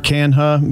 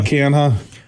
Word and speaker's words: Canha: What's the guy, Canha? How do you say that? – Mark Canha. Yeah, Canha.